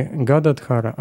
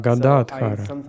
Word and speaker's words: Гададхара: И он сказал: «Но правильно говорить не Гададхара, а Гададхара». Я до сих пор иногда содрогаюсь, когда слышу, как преданные даже в наши дни Очень часто говорят Гададхара. Я вспоминаю, как Прабхупада сказал мне об этом. Если вы обратите внимание на санскрит, то увидите, что правильно говорит Гададхара.